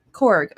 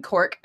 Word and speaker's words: Korg: Korg. Okay, I was going to say it right. I don't know why. Okay. I was thinking Cork at first, and I was like, that is incorrect.